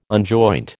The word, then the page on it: unjoint: American English